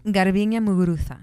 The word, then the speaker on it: Garbiñe Muguruza: Garbiñe Muguruza.